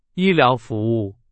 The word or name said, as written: 医疗